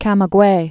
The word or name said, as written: Camaguey